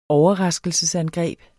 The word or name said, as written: OVERRASKELSESANGREB